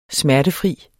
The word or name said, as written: smertefri